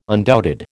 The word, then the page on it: undoubted: American English